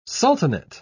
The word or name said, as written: sultanate